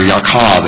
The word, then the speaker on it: Yaakov: Jacob and Yochanan, or Yaakov and Yochanan, as it would have been pronounced in Hebrew.